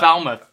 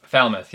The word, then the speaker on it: Falmouth: You'll run the Great North Half September 9th, and then just a week from now, I feel like I still don't know how to say this race. Foulmouth? – Yes, Foulmouth. Foulmouth 7-miler. – Falmouth. – Falmouth, yeah. – There it is.